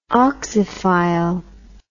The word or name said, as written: oxyphile